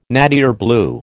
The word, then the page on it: nattier blue: American English